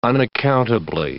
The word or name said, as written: unaccountably